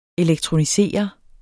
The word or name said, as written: elektronisere